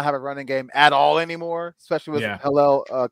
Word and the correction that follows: Edwards-Helaire: Clyde Edwards-Helaire.